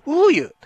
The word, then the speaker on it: OEIL: OEIL と書いて、ね、 OEIL と書いて、ウーユーというアーティスト、